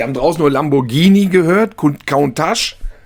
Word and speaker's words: Countach: aber aus der Ecke kommt er und er wird ganz schlicht und ergreifend Countach ausgesprochen, wie die Menschen da vor Ort sprechen, wie die Mundart, das ist die korrekte Aussprache des Lamborghini.